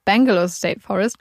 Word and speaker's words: Belanglo State Forest: oder ich glaube, ich meine sogar, dass ich in der zweiten Folge das mhm. dann auch immer genauso ausgesprochen habe, wie man es geschrieben hat, was mich Belanglo State Forest ist. Aber wie auch immer, wo auch immer die Extrasilben herkommen, die Australier nennen es Bangalore State Forest.